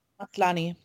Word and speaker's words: Maslany: Maslany.